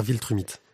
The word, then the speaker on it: Viltrumite: Le invincible, tome 14, les guerres vitrumites. Les guerres Viltrumite. Tu tu prononces très mal.